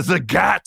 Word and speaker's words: Zagat: And I learned this throughout the process of the acquisition that it is Zagat, like the cat.